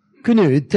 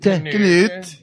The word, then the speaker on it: Cnut: His name is spelled C-N-U-T, the Great. – Knut. – Knut, yeah.